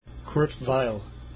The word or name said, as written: Weill